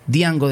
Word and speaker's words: Django: La canción se llama El Primer Beso, Diango de España, o Django, como muchos lo pronuncian.